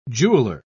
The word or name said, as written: jeweler